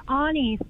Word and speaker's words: Oni: And my name is Ani, spelled O-N-I. – Ani. Why? What's the meaning behind it? – Well, in Japanese, my name is actually pronounced Oni, and Oni is a demon that disguises themselves as women to live in everyday society.